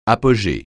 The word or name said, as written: apogée